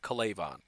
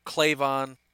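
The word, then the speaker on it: K'Lavon: And with the 14th overall pick in the 2020 NFL Draft, the Atlanta Falcons select Kalaivon Chaisson, edge rusher, LSU. And I've heard that offense, that first name pronounced seven different ways. That's what I'm going with, Kalaivon. I've heard Kalaivon, I've heard Clavon, whatever.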